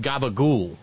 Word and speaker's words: gabagool: I've been looking around for some C-A-P-I-C-O-L-L-A ham, which I understand gabagool. Up east is pronounced gabagool.